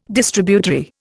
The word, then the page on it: Distributary: Indian